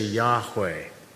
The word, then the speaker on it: Yahweh: Uh, replacement name that the scriptures give to, to God so often throughout the Old Testament is the YHWH, the consonants that don't even have the vowel pronunciation, but we, we say Yahweh.